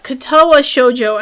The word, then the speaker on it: Katawa Shoujo: Okay, so yeah, Katawa Shoujo, I think I'm saying it correctly. – Yeah, that's right.